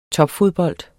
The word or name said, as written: topfodbold